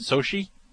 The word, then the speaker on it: Sochi: That's how you pronounce it? Sochi? I believe so.